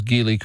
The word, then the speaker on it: Geely: You know, you're one of the few people that pronounces Geely correctly. I checked. It is a hard G, not a soft G, but most people say Geely.